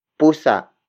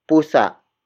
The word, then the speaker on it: pusa: For example, nagising ang pusa natin. Nagising ang pusa natin. Nagising ang pusa natin. It means our cat woke up. Word for word, it's nagising ang pusa natin. Nagising ang pusa natin.